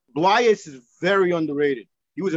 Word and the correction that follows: Blais: I think we pronounced that Blais.